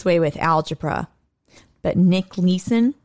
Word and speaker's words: Leeson: Yeah, but he's British, so please, you have to pronounce his name right. It's Nick Leeson. – Nick Leeson. – It's Nick Leeson.